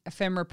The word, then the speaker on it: ephemera: I'm doing moment of colour because I have been doing the ephemera range, ephemera.